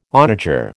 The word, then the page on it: onager: American English